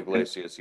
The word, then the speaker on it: Iglesias: They traded their closer Iglesias. Mm-hmm. Am I saying that right? I think I'm saying that right. – Right. Raisel Iglesias.